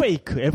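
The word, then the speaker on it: fake: 페이크. F 발음 중요해. 네. F 발음 네, 주의해 주시고요.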